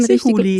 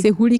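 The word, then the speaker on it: Sehouli: Und da hat Dr. Halit, Sehuli, wie spricht man ihn Sehuli. – Richtig? Sehuli, genau.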